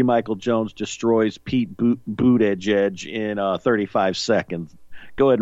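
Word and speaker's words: Buttigieg: Pete Boot Edge Edge. That's how he pronounces it.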